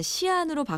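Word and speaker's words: 시안 (西安): 지금은 서안, 중국 발음으로 하면 시안으로 바뀌었는데요.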